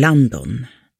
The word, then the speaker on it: Landon: De döper pojken till Landon, lång backe. Uttalat med backens svenska A-ljud.